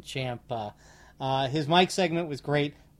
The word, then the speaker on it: Ciampa: And notice I said Ciampa. – Yep, because guess what? We found out the correct way to say it because Ciampa, apparently, he tweeted about the pronunciation. Very strange timing. 8 hours after our podcast dropped, approximately, give or take, he tweeted out, for all you ignorant podcasters out there and journalists, it's pronounced Ciampa.